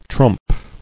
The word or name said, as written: Trumping